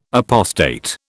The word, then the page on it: apostate: British English